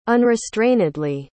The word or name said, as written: unrestrainedly